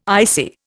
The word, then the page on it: ICI: Indian